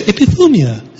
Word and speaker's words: epithumia: I think it's epithumia. Okay, I even looked it up. I went onto Google and I said, pronounce this word. And there's this girl who says, epithumia, epithumia, epithumia.